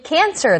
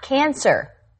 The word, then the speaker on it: cancel: No, you said she should cancel the concert. – Right, that's what I said. – No, it's cancel, not cancer.